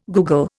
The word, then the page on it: googol: Indian